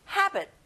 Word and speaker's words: habit: 습관, habit. 끝에 T 발음을 내지 마세요. habit. Very good.